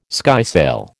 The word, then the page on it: skysail: American English